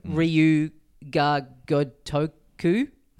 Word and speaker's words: Ryu Ga Gotoku: Ryu Ga Gotoku. – Like a Dragon. – I've butchered that. – No, you said it perfectly, but slowly.